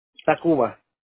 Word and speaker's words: Tacuba: Tacuba. – Okay, ¿cómo se escribe? – T-A-C-U-B-A.